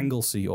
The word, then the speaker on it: Anglesey: How do you pronounce it again? – Anglesey or Ynysmon is the Welsh.